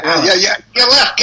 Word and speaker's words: Aleph: But anyway, that's why I was thinking of the Aleph. – Yeah. Aleph. Got it. Right. I think you spell it A L E P H.